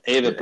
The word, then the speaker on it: Avett: Avett Brothers, you got it.